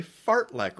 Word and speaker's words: fartlek: And I had a run today called a fartlek run. And it literally sounds, it's spelled just like it sounds. It's a fart, F-A-R-T, and lek, L-E-K.